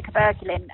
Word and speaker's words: cabergoline: Yeah, it's probably the cabergoline. – Okay, oh, that's how you say it, cabergoline.